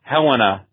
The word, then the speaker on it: Helena: Just had to call, though, to make one suggested pronunciation correction. It's Helena, Montana.